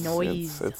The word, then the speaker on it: Noyes: Oh yeah, it's Noyes or Noisy. It's Noyes.